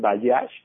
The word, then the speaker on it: Bajaj: conocerla a través de las redes distribuidores Bayash se escribe Bajaj, las dos con J,